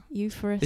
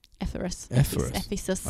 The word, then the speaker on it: Ephesus: Euphorus. – Isn't that? Isn't that a dickhole? – Ephesus.